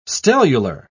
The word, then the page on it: stellular: British English